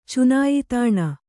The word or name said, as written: ಚುನಾಯಿತಾಣ